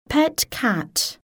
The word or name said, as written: pet cat